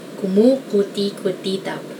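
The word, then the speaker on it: kumukutikutitap: Kumukuti-kutitap. I guess the only way to pronounce this is to remember all the syllables.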